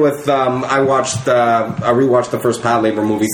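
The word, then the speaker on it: Patlabor: It's Pat Labor. – Yeah, Pat Labor. Okay. Yeah, it's like patrol labor, but like shortened into.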